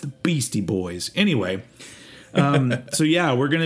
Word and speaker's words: Beastie Boys: If you haven't figured it out yet, the Beatsy Boys or the Beastie Boys. I always really liked that about them. That once. Once there was that track with Biz Markie mispronouncing their name as Beatsy Boys,